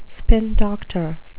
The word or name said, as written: spin doctor